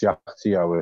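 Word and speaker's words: Kyaiktiyo: Although it's pronounced Jagtio, it's spelled very differently. It's K-Y-A-I-K-T-I-Y-O.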